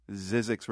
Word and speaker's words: Zzyzx: Zizek's Road, that's spelled Z-Z-Y-Z-X.